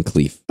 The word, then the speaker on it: Cleef: It's two E's together. I'm going Cleef.